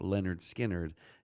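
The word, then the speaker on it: Lynyrd Skynyrd: And before that, Skynyrd from the album pronounced Leonard Skynyrd and Things Going On.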